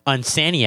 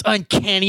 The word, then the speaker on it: Uncanny: So, you know, when I was 11, I always pronounce it Unsanny X-Men. Oh, I love Unsanny X-Men. And my friends would be like, it's Uncanny.